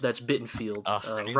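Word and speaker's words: Bittenfeld: Yeah, yeah, no, sorry, that's Bittenfield. Rhymes with Garfield.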